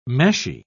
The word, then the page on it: meshy: British English